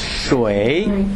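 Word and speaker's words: shui (水): The pronunciation is shui.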